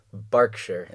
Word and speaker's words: Berkshire: Berkshire.